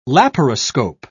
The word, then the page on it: laparoscope: British English